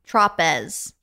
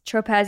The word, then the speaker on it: Tropez: It's spelt Tropez. – Tropez, yeah. It's spelt like Saint Tropez.